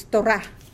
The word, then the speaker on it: Torah: Because most of the time when you see a word that ends with the letter He preceded by the A vowel, the long A vowel, that is where the accent is. So it's Torah. Torah, not Torah. Torah.